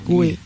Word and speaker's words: Guy: Bueno, jo vaig estudiar història del cinema i ahir no es parlava de Alice Guy. Gui. – S'escriu Gui, però vale.